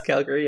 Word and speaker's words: Calgary: Segment three, our metagame we can review, Grand Prix Calgary. I'm not sure if I'm saying that right. Pardon my Canadian. Modern. – Yeah. And that's Calgary, yeah.